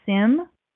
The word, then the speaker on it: Syme: So there's a guy who he pronounces it as Sim, but it's spelled S-Y-M-E. So for what it's worth, S-Y-M-E comes out as Sim for this reader, but otherwise excellent, and he's got a kind of British accent.